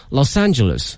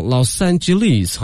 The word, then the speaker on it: Los Angeles: 他这个发音 LA 呢，我觉得有两个发音，一个是美式发音叫 Los Angeles。还有一个叫 Los Angeles，好像是英式发音跟那不太一样。 – 哎，对对对，像我们有的时候用那个英国的教材，他们就把重音放在后面。 – 哦，Los Angeles 啊，好像那方觉得挺怪的。